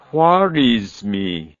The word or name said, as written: Khwarizmi